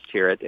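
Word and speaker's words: Tyrwhitt: Charles Tirrett. It's spelled, the last name is T-Y-R-W-H-I-T.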